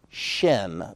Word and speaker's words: shin: If that diacritical dot is located over the right prong, it has the sh sound to it, like she, shin.